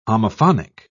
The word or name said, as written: homophonic